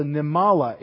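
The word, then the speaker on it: nemalah: And the ant is a Nimala, N-E-M-A-L-A-H. A Nimala, and I'm just pronouncing it the way it says in Hebrew.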